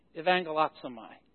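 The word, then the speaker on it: evangelizomai (εὐαγγελίζομαι): It's the word, I know that second letter is a U, but it's pronounced this way, evangelizomai. Because in that ancient time, there was not a separate U and a V. It was the same letter.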